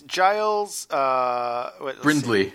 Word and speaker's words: Giles Brindley: It's Giles Brindley.